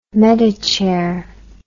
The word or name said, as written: medichair